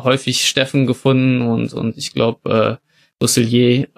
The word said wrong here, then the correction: Roussillon: ähm, oder wie auch ausgesprochen Roussillon. wird, Roussillon, ähm, danke.